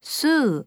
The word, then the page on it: 吸う: すう